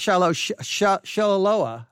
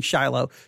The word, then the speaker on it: Shiloah: And it's not Shiloah, I think is how he pronounced it. Shiloah, all right? I think I was saying Shiloh. Shiloah, there you go.